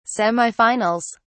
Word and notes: semifinals: British English

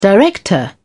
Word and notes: director: British English

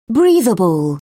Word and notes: breathable: British English

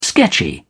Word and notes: sketchy: British English

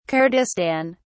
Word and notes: kurdistan: British English

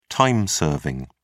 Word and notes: time serving: British English

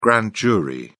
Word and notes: grand jury: British English

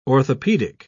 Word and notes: orthopedic: British English

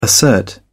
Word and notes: assert: British English

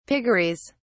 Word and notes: piggeries: British English

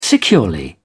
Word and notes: securely: British English

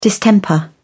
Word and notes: distemper: British English

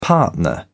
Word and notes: partner: British English

